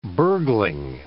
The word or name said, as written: burgling